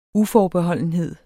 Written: uforbeholdenhed

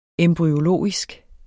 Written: embryologisk

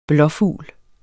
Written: blåfugl

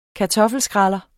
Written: kartoffelskræller

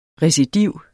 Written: recidiv

